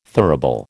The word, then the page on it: thurible: American English